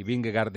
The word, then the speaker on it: Vingegaard: Por cierto, para situar a los oyentes que oirán el mismo ciclista de dos maneras dicho, Vingegaard dice Eri y Vingegaard decimos el resto de los mortales, tiene razón Eri en cómo lo pronuncia, porque así se pronuncia en danés, y si no, escuchen a esta aficionada que danesa que nos lo va a confirmar.